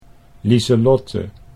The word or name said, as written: Liselotte